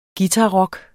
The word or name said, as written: guitarrock